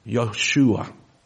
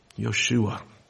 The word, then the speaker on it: Yeshua: The Hebrew pronunciation of that word is something to the effect of Yeshua. Yeshua.